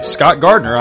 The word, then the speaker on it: Scott Gardner: And hey, you can friend me, Scott Gardner, on Facebook too. My name is spelled ScottGardner.